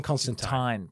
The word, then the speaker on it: Constantine: And Alan Moore, who created John Constantine, has said in no uncertain terms, it's Constantine, not Constantine. And there are actually many panels in the book where he corrects people. – That is now written in. – Rhymes with wine, not teen.